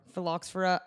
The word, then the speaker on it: phylloxera: Phloroxia, how do you say it? – Phylloxera, I don't know.